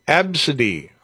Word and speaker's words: ABCDE: The name ABCDE, pronounced Absidy.